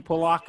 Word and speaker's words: Pulock: Is that how you say his name, Pulock? – Pulock, yep. Yeah.